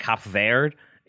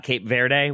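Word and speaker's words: Cabo Verde: I think because they're Portuguese, it's actually like Cap Verde. Is how you pronounce the name of the country, not Cape Verde, which is what I said.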